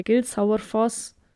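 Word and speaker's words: Gilsárfoss: Der Gilsauerfoss im Tal Gilsauergil. Und nicht, dass ihr das jetzt mit Au schreibt, es wird mit einem A mit Apostroph geschrieben, aber es wird halt immer wie Au ausgesprochen.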